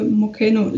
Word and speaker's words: Mocheno: If it helps, it's spelled M-O-C-H-E-N-O. – M-O-C-H-E-N-O. – So if it was like named by in Spanish, then you would have to say it Mocheno. – I'm gonna guess Asia. – I just feel like the E-N-O at the end makes me think of Spanish, so I'm gonna say.